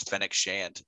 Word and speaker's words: Fennec Shand: One of those side characters that I was super excited to see was Fennec Shand.